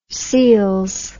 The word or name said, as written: seals